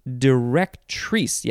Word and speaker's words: directrice: Now wait, directrice. Yes, emphasis is on the last syllable.